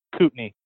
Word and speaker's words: Kootenai: Kootenai.